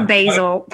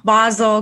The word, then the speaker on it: Basel: You have Art Basel. I think I'm saying that right. I always call Basel. That's not right. Basel coming up.